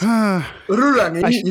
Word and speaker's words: Rurangi: Is the showrunner and director for Rurangi. Am I saying that right? – No, you're not. It's Rurangi. You kind of roll your eyes, get a bit of that sort of Scottish brogue in there.